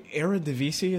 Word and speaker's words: Eredivisie: Yeah, the Eredivisie, is that how it's pronounced? – Yes, I believe so.